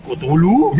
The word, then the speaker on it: Cthulhu: This which you have drawn it is not easy to pronounce. It is Cthulhu."